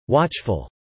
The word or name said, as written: watchful